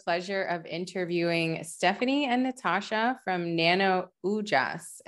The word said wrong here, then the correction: Ojas: Am I saying that correctly? Close, Ojas. Ojas. Ojas.